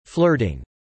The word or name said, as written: flirting